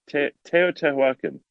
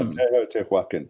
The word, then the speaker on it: Teotihuacan: Well, how do you say it? – Tehotihuacan. – Teotihuacan. – Yeah, Tehotihuacan.